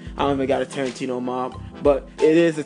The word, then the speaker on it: Tarentino: It's YB Tarentino, Y B T A R E N T I N O. A lot of people misspell it with the A, like Quentin Tarantino, but it's Tarentino with an E.